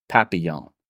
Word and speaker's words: papillon: It starts with a P, and if I have my French pronunciation correct, it is papillon.